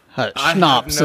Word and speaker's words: schnapps: Schnapps is, I believe, how it's pronounced. – Oh, schnapps. – Yeah. – Oh, schnapps. – German word. Yeah.